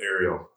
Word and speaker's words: Aerial: So this is called The Aerial? Am I pronouncing that? – Correct. – Right? – Yeah, The Aerial.